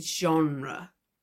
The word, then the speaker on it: genre: And although we do have English words ending in RE, especially in British English, we rarely pronounce them the way we do with genre. For example, the British English theater or center are more of an extension of the T, like ter, rather than pronouncing the R and then the E, like genre.